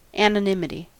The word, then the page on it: anonymity: American English